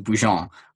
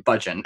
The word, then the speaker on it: Budgen: And fun fact about my last name, it's French. So in Quebec, it is pronounced Bougeon.